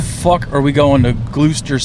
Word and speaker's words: Gloucester: Then there's a lot more vowels and consonants than it would be that I would think Gloucester is spelled. – Yeah, you can knock the U out of there, the C, one of the E's.